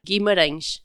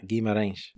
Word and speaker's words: Guimarães: We went to a neat town called help me with the pronunciation, is it Guimarães? – Guimarães, yeah. – Guimarães? – Yeah, because it has the little wave that makes you like need to pinch the nose. – It's more nasally. – Yeah. – Guimarães. – Yeah. – Okay, good.